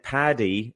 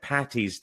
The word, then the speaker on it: Paddy: By the way, St. Paddy is spelled P-A-D-D-Y. Paddy is not short for Patricia. It's short for Patrick. So don't say St. Patty's Day with a T.